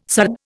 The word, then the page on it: sard: Indian